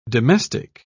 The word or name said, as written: domestic